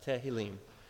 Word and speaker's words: Tehillim: And the word for psalms actually in Hebrew is pronounced Tehillim.